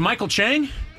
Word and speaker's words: Michael Chang: Was it Michael Chang? – Yes.